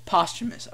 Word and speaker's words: posthumous: How do you actually pronounce that? – Posthumous.